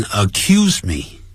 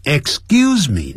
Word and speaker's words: excuse: 首先我们来看 excuse me，这个我们已经教过了，不要念成 accuse me， accuse somebody 那指控某人呢、啊、那是 accuse， don't accuse me，不要呢指控我。 excuse me 呢 excuse 后头念念死音，后头念 g Excuse me，不要念成 excuse me， excuse me，对不起，打扰一下，叫做 excuse me，不好意思，就相当于中文这样的意思。